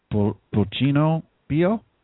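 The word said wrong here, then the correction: El Pollito Pío: No. The song is called El Pollito Pio. El Pollito Pio.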